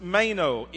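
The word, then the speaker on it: meno (μένω): In the Greek, it's pronounced meno, M-E-N-O is written in the English language, but it's in the aorist tense in the Greek.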